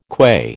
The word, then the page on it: qua: American English